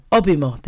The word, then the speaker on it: Obimo: And Adekunle Gold starting off that Afrobeat stack with Ibimo. Did I say it right? No, Obimo. There you go.